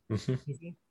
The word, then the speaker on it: Schepisi: And maybe even the director as well was alluring, because looking at his filmography before The Russia House, would you pronounce it Schepisi? – Schepisi.